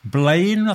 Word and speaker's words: Blaen: And if you were listening to the show a couple of weeks ago, you'll have heard me play a track by the band Blaine, I think they're pronounced, B-L-A-E-N.